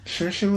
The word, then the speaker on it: Saoirse: It's the one that her name is spelled S-A-O-I-R-S-E. – She's Hannah. – And, like, yeah. She came out in Hannah. She's a really cool actress. But it's pronounced Saoirse, which I love, by the way.